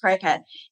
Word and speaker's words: Cricut: And cricket is spelled C-R-I-C-U-T. And for years, I was pronouncing this cricut.